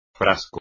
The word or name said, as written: frasco